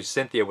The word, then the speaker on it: Sinthia: Her name is actually Cynthia with a C, but they spell it S-I-N-T-H-I-A.